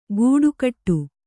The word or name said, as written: ಗೂಡುಕಟ್ಟು